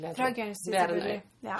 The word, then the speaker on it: Guernsey: Og Guernsey, er det riktig uttale? Det er riktig. – Guernsey.